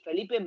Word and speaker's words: Felipe: It's Felipe Martins is the official Brazilian pronunciation of this midfielder,